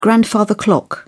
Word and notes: grandfather clock: British English

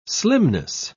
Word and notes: slimness: British English

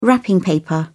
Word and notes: wrapping paper: British English

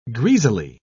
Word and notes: greasily: British English

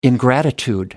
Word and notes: ingratitude: American English

